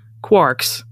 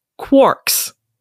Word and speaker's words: quark: You might be noticing, or you might have noticed, that the word is spelled Quark, which would imply that it should be pronounced quarks. rhyming with parks, but it is in fact pronounced quarks, rhyming with pork.